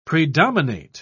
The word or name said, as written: predominate